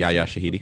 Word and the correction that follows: Yara Shahidi: I was about to ask how you say her name. – Yara Shahidi. – Yara. – Yes. Mm-hmm. And it's basically. – I think I said Yaya. That's my bad.